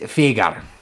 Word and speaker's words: Fayger: Fagger. – Say that again, I'm sorry. Your accent's really thick. – I suppose we can pronounce it differently. We'll pronounce it Fagar. – F-A-Y-G-E-R.